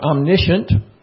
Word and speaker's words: omniscient: That's spelled like omniscience, but it's pronounced omniscience or omniscient.